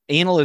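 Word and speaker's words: Anıl: A-N-I-L-K-O-C. But the article I read literally said, even though it's spelled with an I, it's pronounced as an A. – Like... We could pronounce it Anil, but let's make things really hard.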